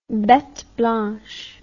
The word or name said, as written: bete blanche